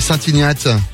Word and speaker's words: Saint-Ignat: Saint-Ignat, ou on prononce l'été, on dit Saint-Ignate.